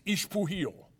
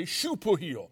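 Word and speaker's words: Xpujil: It's pronounced according to who's pronouncing it. If it's pronounced in a typical Mexican way, it's Ixpuhil. If it's pronounced like the Maya, they say Xupuhil.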